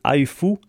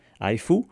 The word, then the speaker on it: Hehfu: On va se quitter avec les Britanniques de Haifu, ça s'écrit H-E-H-F-U, Haifu.